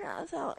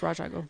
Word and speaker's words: Ra's al Ghul: Okay, like Wikipedia is pretty definitive. And it's Ra's al Ghul. It's spelled like Ra's al Ghul, but like I get why they pronounce it that way. – It's Ra's al Ghul.